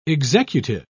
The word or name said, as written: executive